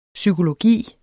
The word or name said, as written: psykologi